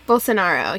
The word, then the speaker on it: Bolsonaro: Bolsonaro, yeah.